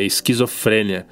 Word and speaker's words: esquizofrenia: Esquizofrenia? – Não, não, é esquizofrênia. Tem acento nesse aí. Escreve com S, C, H, depois tem também P, tem outro H, tem dois Hs.